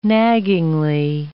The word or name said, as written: naggingly